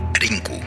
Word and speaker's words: Link: aunque por defecto su nombre es Link, o por su pronunciación en japonés, Rinku.